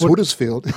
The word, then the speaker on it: Huddersfield: You got that right, but you got this one wrong. It's Huddersfield. – Can't win them all. – No. I mean, look, if you come from Australia, you can say Huddersfield.